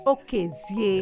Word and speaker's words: Okezie: Well, yes, uh, but it's pronounced Okezie. – Okezie. Yeah. Ah, there's a cadence that I missed.